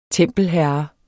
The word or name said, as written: tempelherre